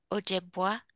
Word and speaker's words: Ojibwa: It's the correct pronunciation for it is the tribe is Chippewa Ojibwa. Ojibwa is what we call ourselves, and Chippewa is what we're in government records.